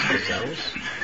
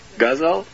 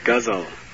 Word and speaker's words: ghazal: Gazelles? – Gazelle is a type of animal, like a buffalo, but... it's Hindi love songs. What? – Ghazal. – Like guzzling. – Yes, guzzle. I'm sorry for my improper pronunciation.